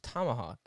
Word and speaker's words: tomahawk: But it's Tomahawk, isn't it? Isn't that how the word's pronounced? No, it's like a book, a tome.